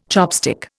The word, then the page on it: chopstick: Indian